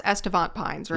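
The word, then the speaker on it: Estivant Pines: Yeah, so a thing I'm working on right now is months ago, before winter, wrote this script about, it's pronounced Estevant Pines, right? Mm-hmm. Okay. I said Estevant for a long time. – Estevant, yeah.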